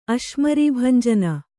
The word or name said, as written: ಅಶ್ಮರೀಭಂಜನ